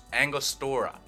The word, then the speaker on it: Angostura: And then you need 2 dashes of your Agnostra bitters. Angostura. I always fucking pronounce them wrong. Angostura.